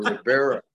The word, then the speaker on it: Rivera: Rivera.